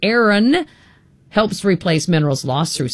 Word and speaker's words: Ayran: It's called, I'm not sure if I'm pronouncing it correctly, Ayran, A-Y-R-A-N, Turkey's beloved yogurt drink.